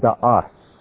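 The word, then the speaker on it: Da'as (דעת): And the word down here is the word da'as. D-A-A, you put an S or a T. Da'as is easier to pronounce, so I put an S there.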